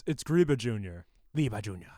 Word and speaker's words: Griba: They pronounce Griba the same way we do. – Griba. – Griba.